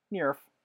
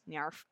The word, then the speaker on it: NIIRYF: Those are the letters for Not If I Reboot You First, and it is pronounced Nierf. – You can also find this podcast on Instagram at Not If I Reboot You First, all one word. The hashtag is NIIRYF, also pronounced Nyarf.